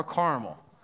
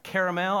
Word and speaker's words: caramel: Is it caramel or Carmel? Which one is it? Turn to your neighbor and ask them, how do they say the word caramel or Carmel? What's the right way to say it? Now, the right way where I come from to say the word is caramel.